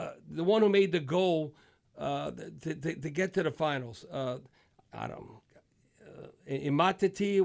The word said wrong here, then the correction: Umtiti: whatever. He was born in Cameroon. And then Bakbe. – Umtiti. – Yeah, how you say it? – Umtiti. According to Mark Renard.